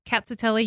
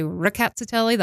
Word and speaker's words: Rkatsiteli: It's a bit this interesting because it's spelt with an RK. Yeah. So you hear us say Rkatsiteli. You'll see some people say Ricazzatelli. They'll hit that R hard.